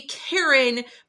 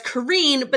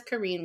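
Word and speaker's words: Karin: It's K-R-I-N. – I still think it's Karen. – The audiobook narrators say Karen, but she says it in YouTube as Kareen, but then I've heard, why would she approve the audiobook to say her name is Karen if she's saying it Kareen? I don't know, and I don't want to upset her. – Well, we'll go with the way she says it. If she says Kareen, we will say Kareen.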